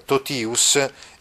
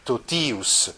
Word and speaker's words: totius: Ah, ecco, notate che questa desinenza in ius del genitivo ha la quantità lunga, pertanto si pronuncia così, totius, solius, ecco proprio totius è uno di quei casi del gruppo ti seguito da vocale ma accentato, si legge T.